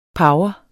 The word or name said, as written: power